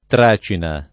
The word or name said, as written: tracina